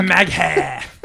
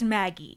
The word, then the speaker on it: Maggheeh: M-A-G-G-H-E-E-H. – It's a big lock. – Maggie. – Pronounced Maggie.